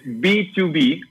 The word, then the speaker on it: B2B: Es B2B o B2B, como business to business.